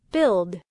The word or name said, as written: build